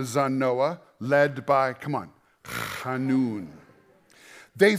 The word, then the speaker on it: Hanun: Did you like my pronunciation there? That's super Hebrew. Hanun. You want to try with me? They get that little thing in the back of the throat.